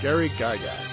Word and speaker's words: Gygax: Dolman's got a thing in the chat on how to say his name. – Gygax. All right. – There you go. – All right. Gary Gygax.